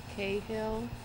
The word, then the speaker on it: Cahill: My name is Deborah Sherlene Cahill. It's spelled D-E-B-O-R-A-H-S-H-I-R-L-E-N-E-C-A-H-I-L-L.